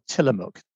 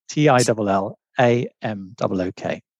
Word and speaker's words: Tillamook: It's called Tillamook. T I L L A M O O K.